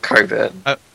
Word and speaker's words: Cockburn: We prefer to pronounce Cockburn without the CK, so we call it Coburn.